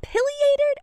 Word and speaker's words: pileated: Tell me, is it pileated or pileated or pile? How do you say it? – Both are acceptable.